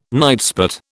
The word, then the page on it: nightspot: British English